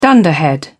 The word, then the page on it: dunderhead: British English